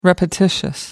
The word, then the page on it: repetitious: British English